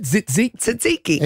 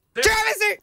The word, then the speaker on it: tzatziki: What, how do you say it? – Tzatziki. That's the cucumber one.